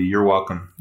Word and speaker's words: you're welcome: And I hear a lot of learners will say you are welcome, which this one, it's always said as a contraction. So it's just one syllable, you're welcome.